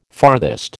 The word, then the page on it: farthest: American English